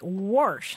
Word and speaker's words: wash: So, pronouncing W-A-S-H like warsh, huh? – Uh-huh.